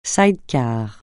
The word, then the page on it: side-car: francais